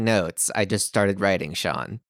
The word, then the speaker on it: Sian: I mean, it's spelled S-I-A-N, I think. But yeah, I'm pretty sure they were just saying Sean.